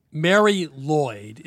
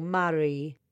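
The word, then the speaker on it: Mari Lwyd: Merry Lloyd, is that the right way to pronounce what we're about to talk about? – Yeah, kind of. I know it's your lovely accent, but we say Mary a bit more. Mary.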